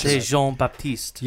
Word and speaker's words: Jean-Baptiste: I'll assume that's how it's pronounced, the French Jean-Baptiste.